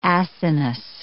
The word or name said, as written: acinous